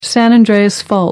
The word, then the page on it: san andreas fault: American English